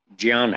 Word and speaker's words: ज्ञान: गुजरात के लोग ज्ञान या इस तरह से बोलते हैं हम उसको हिंदी संस्कृत में संस्कृत में उसका उच्चारण ज्ञान है